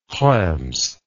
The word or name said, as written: krems